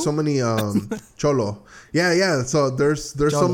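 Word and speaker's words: Xolo: Yeah, it's pronounced Cholo. – Oh, is it? – Yeah. – Is it Cholo? Is it? Yes, no, it's Cholo.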